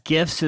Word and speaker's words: GIFs: That's GIFs is the correct pronunciation of it.